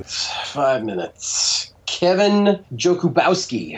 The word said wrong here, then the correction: Jakubowski: I think it's pronounced Jakubowski.